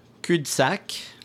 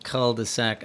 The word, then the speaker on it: cul-de-sac: The pronunciation of that one is, well, in France, they would say cul-de-sac. You know, so it's not cul, it's cul. – Cul. – Cul. But I would recommend for English speakers that they stick with cul-de-sac.